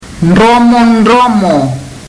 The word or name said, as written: dromodromo